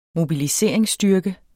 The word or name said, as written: mobiliseringsstyrke